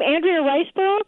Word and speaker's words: Andrea Riseborough: And is it her name Andrea Riseborough? – Yeah.